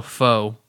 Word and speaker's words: pho: I guess it's pronounced pho, but I don't like saying that. So pho, of course.